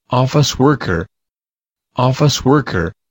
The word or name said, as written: office worker